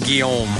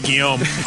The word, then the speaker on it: Guillaume: I suppose it was, and we apologize for not having anything about Benson, but it's very difficult to rhyme with his last name, Guillaume. – Yeah, Guillaume. Although, here we go. We lost a legend named Robert Guillaume. The Lord has asked him to come on home.